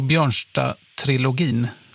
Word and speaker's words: Björnstad-trilogin: Så roligt att du har hittat honom och Björnstad-trilogin, var det rätt uttalat? – Ja, precis.